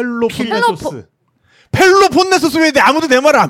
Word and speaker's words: 펠로폰네소스: 스파르타가 있는 그 필로폰네소스. – 네. – 필로폰네소스. – 펠로폰네소스.